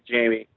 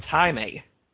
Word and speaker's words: Jaime: He is supposed to be Jamie. – No, it's Jaime. – Yeah, Jaime Reyes. – Let's see. Pronounce it. – He's Hispanic. The J's are pronounced as H.